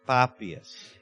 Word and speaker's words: Papias: And he quotes a man, his name is hard to pronounce, it's Papias.